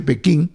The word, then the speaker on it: Pekín: Bueno, Pekín, que es la capital, hay gente que lo pronuncia diferente. A mí me enseñaron que se dice Pekín, expresa su fuerte insatisfacción con las declaraciones que dieron los miembros del Grupo C en Francia sobre Hong Kong.